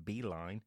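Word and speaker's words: Beilein: I believe it's pronounced Beeline.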